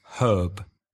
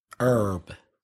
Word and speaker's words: herb: So the Americans do not pronounce the H at the front of herb, where British English speakers do. They say herb. – And in American, herb.